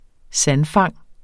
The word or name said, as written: sandfang